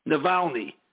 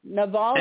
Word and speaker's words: Navalny: How do you say it? – Navalny. – Navalny. – Navalny. Yeah.